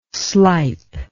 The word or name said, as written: slipe